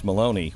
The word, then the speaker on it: Maloney: By the way, that question came from Miss Maloney, which rhymes with baloney.